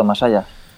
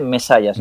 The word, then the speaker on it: Masaya: Bueno, NCS Corporation y una compañía, corrígeme si me equivoco, que se llama Masaya, no sé si lo he dicho bien. – Correcto, Masaya. – Bueno, bueno, en japonés se dice mesaya, si mesaya. Nos ponemos ya en plan puristas.